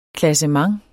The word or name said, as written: klassement